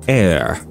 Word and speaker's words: heir: He wants to be the only heir. Heir means, well, it's pronounced as heir, like heir. But it's written with an H. E-I-R, heir.